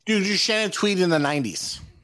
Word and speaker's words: Shannon Tweed: Shannon Tweed.